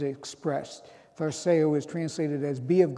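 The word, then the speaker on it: tharseo (θαρσέω): In Greek, it's pronounced tharseo, tharseo.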